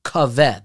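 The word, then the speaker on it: kav (קו): And if you take the word for circumference in this pasuk, which is kaveh, that's how it's spelled, but it's pronounced kav without a hey, and you take the gematria of those two words, look it up in the pasuk.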